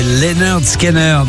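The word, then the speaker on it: Lynyrd Skynyrd: RTL2 et Leonard Skinner. Oui, ça se prononce comme ça, je sais, c'est bizarre.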